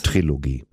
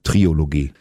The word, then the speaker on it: Trilogie: Trilogie. – Was habe ich gesagt? – Triologie. Ich bin Klugscheißer, schreibt man mit K und der Trilogie lässt man das O weg.